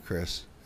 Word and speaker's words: Kris: Chris because, I don't know, we're just staying with the whole theme of C, even though my name is spelled with a K. I'm your host, by the way, Chris, and I am sitting here with the beautiful Patty.